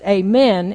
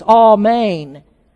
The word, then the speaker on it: Amen: It gets into the English pronounced Amen. In Hebrew, it's Amen.